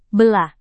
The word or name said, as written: belah